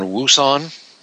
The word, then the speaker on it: Wouxun: The way you pronounce it is open to discussion, but it's most people settle on Wouxun.